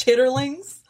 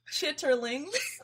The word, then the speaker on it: chitterlings: It's actually, do you know it's actually pronounced chitterlings? If y'all can see the way Jordan is just so surprised by that. – Wait, I had to take my headphones off. – What? It's pronounced chitterlings.